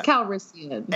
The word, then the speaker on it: Calrissian: Oh, this is why you mispronounced his name also. Yeah. It's Calrissian.